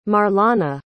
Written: marlana

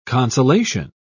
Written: consolation

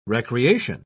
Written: recreation

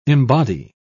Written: embody

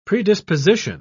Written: predisposition

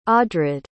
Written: audrad

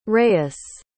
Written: reyes

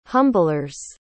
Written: humblers

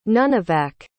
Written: nunivak

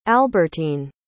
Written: alberteen

